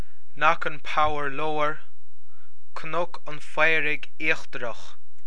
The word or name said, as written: Cnoc an Phaoraigh Íochtarach